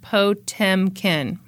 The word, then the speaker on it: Potemkin: Oh, also, it's pronounced Potemkin. I wonder how I pronounced it. Probably not that way.